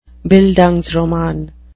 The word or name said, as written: bildungsroman